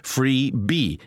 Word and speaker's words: freebie: And again, the only reason for this B sound is it rhymes with free, freebie.